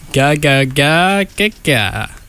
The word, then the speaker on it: Ga Ga Ga Ga Ga: That was The Underdog by Spoon off of the album Ga Ga Ga Ga Ga.